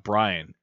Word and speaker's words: Bryan: Like, I got Brian.